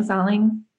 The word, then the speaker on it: Salling: But then Mark, I think it's pronounced Salling. – Salling.